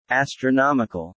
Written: Astronomical

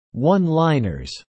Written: one-liners

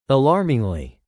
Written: alarmingly

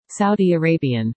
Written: Saudi Arabian

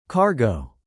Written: cargo

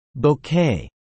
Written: bouquet

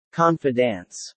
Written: confidants

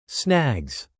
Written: snags

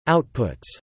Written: outputs

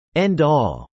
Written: end-all

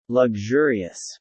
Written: luxurious

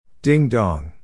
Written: ding-dong

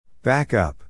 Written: back up

